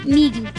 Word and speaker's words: migi: Morir se dice migi. – Migi. – Migi, así como con G.